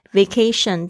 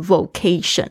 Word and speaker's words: vacation: 后面接的一个形容词子句， I don't need a vacation from， I don't need a 就是我不需要， vacation 就是指假期。大家特别注意一下这个发音，我以前刚学英文的时候我也发错，我会讲成 vacation，然后我现在才发现我的学生会说成 vocation，然后会拼成 V-O-C-A-T-I-O-N，那个就变成一个天职，那就不对了，所以是 vacation， V-A-C-A-T-I-O-N 是指度假，是指假期的意思。